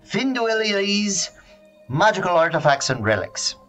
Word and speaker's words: Finduilies: I'll spell it for you in case you didn't know how to say it. That's an F-I-N-D-U-I. Don't want one of those. L-Y-E. God's comma. S. Finduilies. One more time. F-I-N-D-U-I. Again, don't want one of those. L-Y-E.